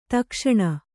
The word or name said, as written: ದಕ್ಷಿಣ